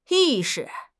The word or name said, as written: 意识